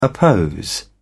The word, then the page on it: oppose: British English